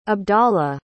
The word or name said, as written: abdalah